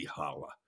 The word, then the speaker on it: challah: I've been baking challah for years now, or as some Jews might pronounce it, challah. It's actually holla.